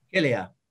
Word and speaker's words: Achillea: Now, how do you say this? Achillea? Achilla? – Achillea.